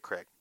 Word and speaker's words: Krieg: Is it Krieg or Craig? – Dave Craig. – Craig? – Mm-hmm. – That's not how it's spelled.